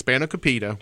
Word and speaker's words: spanakopita: That's spanakopita. – Spanakopita. – Yeah, or spanakopita. I said it wrong for like 20 years. – And then I found out it was spanakopita.